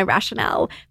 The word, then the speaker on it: Rationale: R-A-T-I-O-N-A-L-E, Rationale.